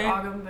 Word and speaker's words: Ogham: It's O-G-H-A-M. It looks like Ogham, but it's pronounced Ogham.